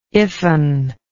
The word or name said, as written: iffen